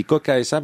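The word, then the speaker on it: Qoqa: Je tiens à préciser que c'est assez astucieux, hein, parce que ça se dit Coca SA, mais ça s'écrit avec deux Q.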